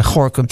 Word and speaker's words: Gorinchem: Ja, en ik struikel af en toe, want ik zie dan altijd Gorinchem staan. En dan denk ik, hoe spreek je dat ook alweer uit? Maar het is natuurlijk gewoon Gorinchem en niet anders.